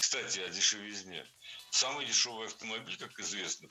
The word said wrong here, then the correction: дешевизне: Ведь в слове «дешевизна» ударение всегда падает на третий слог. Поэтому должно быть «кстати о дешевизне».